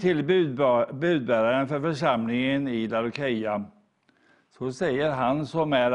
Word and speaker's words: Laodicea: Idag ska vi också gå till Uppenbarelseboken och vi ska gå till det sista brevet till församlingen i Laodicea eller Laodikeia, det kan uttalas på olika sätt.